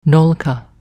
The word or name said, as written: nolka